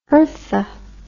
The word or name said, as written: Hertha